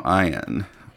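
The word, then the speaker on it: Eyen: Yeah, Krieger and Tom Ian.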